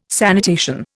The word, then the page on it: sanitation: Indian